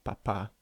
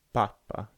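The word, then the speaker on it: papá: So mama, papa. So the stress in each case is on the second syllable. So you can't say papa, can you? – No. – Sounds really strange.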